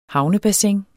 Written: havnebassin

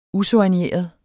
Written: usoigneret

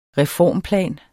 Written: reformplan

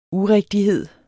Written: urigtighed

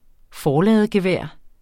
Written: forladegevær

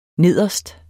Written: nederst

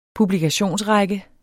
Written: PUBLIKATION

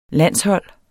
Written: landshold

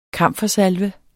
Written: KAMFER

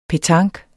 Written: petanque

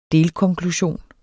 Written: delkonklusion

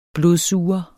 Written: blodsuger

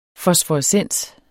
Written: fosforescens

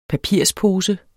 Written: PAPIRPOSER

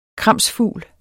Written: kramsfugl

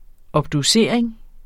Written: obducering

